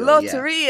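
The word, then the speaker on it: Lotería: Also shout out to Mike, who created Millennial Latiraria. Okay, help me out with the pronunciation, because this is Mexican bingo. Did I pronounce it right? Latiria? – Mexican bingo. – Loteria. – Yeah.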